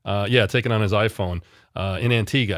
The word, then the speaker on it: Antigua: And that's the correct pronunciation of Antigua, by the way. And I know that from my friend Terry correcting me earlier. So thank you for that. I think I had said Antigua, which is totally incorrect.